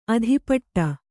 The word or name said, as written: ಅಧಿಪಟ್ಟ